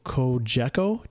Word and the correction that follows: Cogeco: TV? – Cogeco. – That Cogeco. Cogeco. Oh, so close. Right. I had the uh, emphasis on the wrong syllable there.